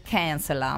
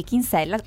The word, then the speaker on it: Kinsella: Al secolo probabilmente si dovrebbe pronunciare Kinsella, ma okay, noi in Italia la conosciamo così, come Sophie Kinsella,